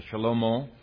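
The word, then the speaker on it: Shlomo: Now, Solomon is pronounced in the Hebrew, Shalomo, and it means his peace.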